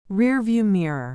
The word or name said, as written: rearview mirror